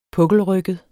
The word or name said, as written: puklet